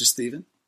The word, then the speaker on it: Stephen: It's starring myself, Mr. Steven. That's spelled with a PH for all you ladies out there. Because everybody wants to say Steven with a V, and they always, since I was a little kid, they would say, is that Stefan.